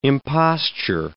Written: imposture

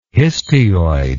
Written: histioid

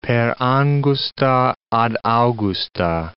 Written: per angusta ad augusta

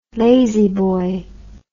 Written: lazboy

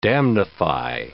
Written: damnify

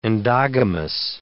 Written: endogamous

